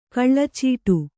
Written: ಕಳ್ಳಚೀಟು

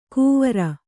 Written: ಕುವರ

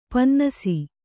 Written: ಪನ್ನಸು

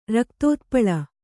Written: ರಕ್ತೋತ್ಪಲ

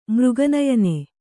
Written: ಮೃಗನಯನೆ

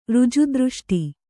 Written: ಋಜುದೃಷ್ಟಿ

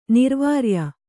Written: ನಿರ್ವಾರ್ಯ

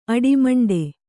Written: ಅಡಿಮಂಡೆ

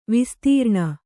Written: ವಿಸ್ತಾರಣ

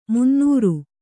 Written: ಮುನ್ನೀರು

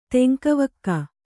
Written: ತೆಂಕವಕ್ಕ

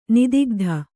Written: ನಿದಿಗ್ಧ

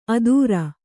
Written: ಅದೂರ

